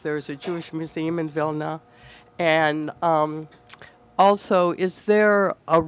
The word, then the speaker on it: Vilna: Now, I always refer to it as Vilna because that's the Yiddish pronunciation.